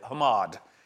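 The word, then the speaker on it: hamad (חמד): hamad. It's spelled, it's more like C-H-A-M-A-D, but it's pronounced Hamad.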